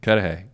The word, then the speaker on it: Cudahy: Cudahy? – Cudahy.